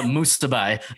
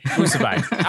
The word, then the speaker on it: Musibay: It's uh, Musibay. Musibay.